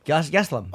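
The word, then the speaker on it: Gastelum: How do you pronounce his last name? – Geslam.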